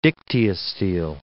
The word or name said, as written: dictyostele